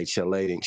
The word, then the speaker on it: chelating: Functional hydroxyl groups of flavonoids mediate their antioxidant effects by scavenging free radicals and or by chelating, I don't know how to pronounce that, chelating metal ions. – Chelating. – Which is, say it again.